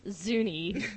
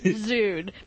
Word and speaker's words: Zune: So, yeah, I'm excited about this codename key chest, even if I pronounce Zuni, Zuni, as opposed to Zune, because I don't own one.